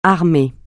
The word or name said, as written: armée